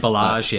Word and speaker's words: Ballage: Yeah, it's Kalen Ballage, yeah.